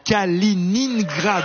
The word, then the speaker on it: Kaliningrad: Kaliningrad, oui, c'est exact.